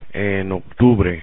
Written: en octubre

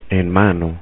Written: en mano

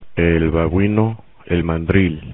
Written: babuino, mandril

